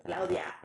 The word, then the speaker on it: Claudia: I realized I've been trying to say my name more the way that it's actually, that my mother says it, you know, Claudia.